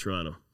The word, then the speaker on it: Toronto: You pronounce Toronto without the second T. – Correct, Toronto.